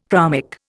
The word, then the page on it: chromic: Indian